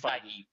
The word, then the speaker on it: Feige: Oh, and Fahey? How do you ever say his name? – Oh, Kevin Feige? I think it's Feige.